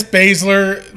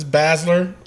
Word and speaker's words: Baszler: Baszler. It's Baszler. Rick, I don't know why he says Baszler.